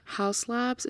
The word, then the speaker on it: Haus Labs: Haus Labs is how I pronounce it. It's spelled H A U S Labs L A B S.